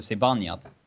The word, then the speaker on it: Zibanejad: Ja, eh, alltså, om vi ska vara helt korrekta så uttalas det Zibanejad. – Okej, okay, ja, bra. Då har jag lärt mig det också. – Zibanejad. In, inget E.